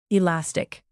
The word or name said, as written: elastic